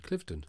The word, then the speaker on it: Cliveden: Clifton. – Cliveden.